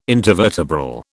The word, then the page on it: intervertebral: British English